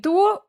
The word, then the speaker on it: dur: Du, o sigui, du, D-U-R, però pronunciem du.